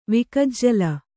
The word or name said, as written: ವಿಕಜ್ಜಲ